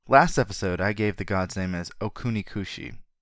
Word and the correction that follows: Okuninushi: which is both incorrect and a mispronunciation of the first letter. The name is Okuninushi, and I will correct that this episode and offer my apologies.